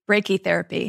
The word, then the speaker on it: brachytherapy: Brachytherapy.